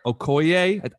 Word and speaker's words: Okoye: People are connecting the dots to the scene in Endgame where Okoye, I think I'm saying that right.